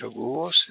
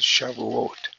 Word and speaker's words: Shavuot: So this is pronounced Shavuos in some circles. In Israel, it's Shavuot. Ends with a T rather than an S.